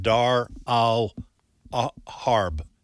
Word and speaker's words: Dar al-Harb: So I think if I get this, the pronunciation right, the Arabic is Dar al-Islam and Dar al-Harb, spelled H-A-R-B.